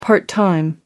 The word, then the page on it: part time: American English